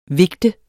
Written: vigte

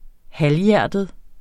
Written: halvhjertet

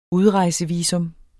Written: UDREJSEVISUM

